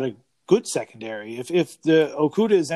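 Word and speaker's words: Okudah: Obviously, they got the corner, Okuda. Okuda, I think that's how you say his name. – Yeah. Okuda.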